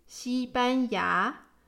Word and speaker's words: 西班牙: Spain in Mandarin is pronounced 西班牙.